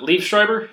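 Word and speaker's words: Liev Schreiber: So my favorite thing is definitely, how do you pronounce it? Liefschreiber? – Yes. – Is that right?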